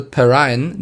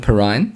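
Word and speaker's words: Perine: Ja, also ich habe viel vom Spiel gesehen, ich habe gelernt, dass LaMichael Perrine nicht Perrin ausgesprochen wird, sondern Perrine.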